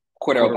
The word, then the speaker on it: Cordarrelle: Cordarrelle Patterson. – Yeah, Cordarrelle Patterson for the remaining of the season.